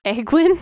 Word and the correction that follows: Egwene: Egwin? I can't do it. I can't do it. – I did it wrong. – Egwene. – There you go. And how do you spell it without looking? – E-G-W-E-N-E.